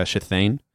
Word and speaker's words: Shithane: Is it S-H-I-T-H-A-N-E? – I, you know what? I've never written it down. – Shathane? – That it's pronounced Shathane, so okay. Write it however you need to write it. – Okay, S-H-I-T-H-A-N-E.